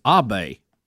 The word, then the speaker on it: Abe: His last name is Abe, is how I have heard it pronounced.